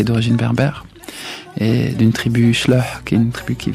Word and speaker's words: Chleuh: Vous le prononcez bien en plus, hein. – Tribu Chleuh. Chleuh.